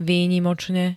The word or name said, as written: výnimočne